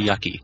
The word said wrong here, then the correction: Yuki: I'm pretty sure it's Yuki, because Yucky sounds bad.